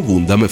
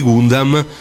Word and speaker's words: Gundam: Ma Sunrise, che tutti sappiamo avere i diritti di Gundam, anzi di Gundam per chi vuole essere purista, però noi continueremo a pronunciarlo Gundam, decise di fare qualcosa di inaspettato.